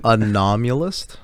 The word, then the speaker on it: anomalous: Anomalous. – Like an anomaly. How do you say it? I can't speak. – Like an anomalous? – Oh, it's like anomaly, right? Anomalous. Anomalous.